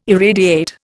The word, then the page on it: irradiate: Indian